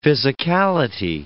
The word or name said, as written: physicality